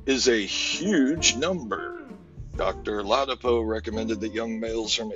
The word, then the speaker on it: Ladapo: Florida Surgeon General released a shocking report on Friday, according to Dr. Joseph Lapado. – Did I say that right? – Ladapo. Oh, see, that dyslexia kicked in.